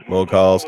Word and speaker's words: Mughals: Mughals, yeah. Mughals. Yeah. As Griffin was telling me, it's like we got our term mogul from it.